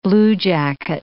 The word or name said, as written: bluejacket